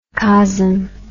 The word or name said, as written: cosm